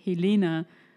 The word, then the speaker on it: Helene: Zum Beispiel eine Sache, die mir aufgefallen ist, ähm, ähm, in der Folge, wo ich dich frage, ob du lieber ähm, Helena Fischer wärst habe ich ihren Namen so komisch ausgesprochen. Ich habe es mir nochmal angehört und dachte mir so, Helena habe ich gesagt und der ist eigentlich Helene.